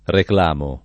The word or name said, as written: reclamo